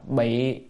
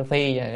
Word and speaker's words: phi: Ca sa phi thể, chữ này đọc là bị phi, đọc cái phi nó đúng hơn, đúng âm phi, đúng phi tức là khoác lên người á.